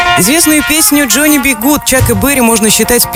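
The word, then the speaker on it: Johnny B. Goode: Кстати, полное имя Джонни Би Гуд произносится на английском, так же как фраза Джонни Би Гуд.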